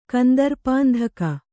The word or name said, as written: ಕಂದರ್ಪಾಂಧಕ